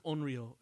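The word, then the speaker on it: Onryo (怨霊): I think Onryo is like. – Oh, okay. – That's how it's pronounced.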